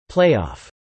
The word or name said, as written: playoff